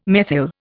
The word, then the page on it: methyl: Indian